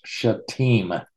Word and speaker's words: shittim: I know it's spelled differently, S-H-I-T-T-E-M, but it's pronounced shittim.